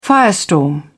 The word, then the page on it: firestorm: British English